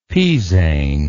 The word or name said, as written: pizaine